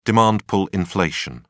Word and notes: demand-pull inflation: British English

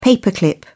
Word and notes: paper clip: British English

